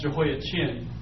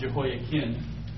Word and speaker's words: Jehoiachin: notice one is spelled K-I-M, Kim, and one is C-H-I-N like chin, but it's not pronounced Jehoiachin. The C-H becomes a K sound, Jehoiakin.